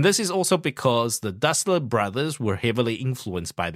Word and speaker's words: Dassler: Well, if you pronounce it the German way, it's Dassler.You got to pronounce the very strongly. The Dasslers.